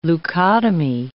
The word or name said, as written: leukotomy